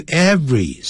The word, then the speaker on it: every: Every morning，不要念成 every morning，不是三个音节，那个 every 是两个音节， every，不要念成 every 啊，没有这样念法的。 Every morning when she woke up，每天早上当她醒来的时候， she would walk down the river，她就会走路啊，就沿着下面走，一直朝下走，走到河边， to the river To eat her breakfast，到那边去吃早餐。